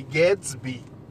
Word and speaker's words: Gadsby: H-A-N-N-A-H. É um palíndromo. E Gadsby. Parece. É, é do Great Gatsby, né? é do mesmo jeito, tá?